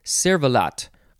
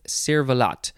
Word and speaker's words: cervelat: Next we have cervelat or cervelat, so you don't have to pronounce the T at the end because it is French noun from 1613 smoked sausage made from a combination of pork and beef.